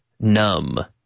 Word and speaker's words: numb: Numb. Yeah. Entumecen, as Kubla says. Your toes go numb. Sintumecen. Entumecidos. No, entumecidos. Numb. They're numb. N-U-M-B with the silent B at the end.